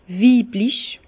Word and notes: weiblich: Bas-Rhin; Herrlisheim

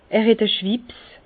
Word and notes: er het e Schwipps: Bas-Rhin; Herrlisheim

